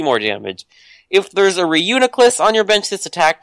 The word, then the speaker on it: Reuniclus: Reuniclus. – Reuniclus.